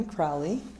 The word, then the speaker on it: Crowley: Julie Ann Crowley, C-R-O-W-L-E-Y.